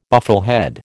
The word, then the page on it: bufflehead: American English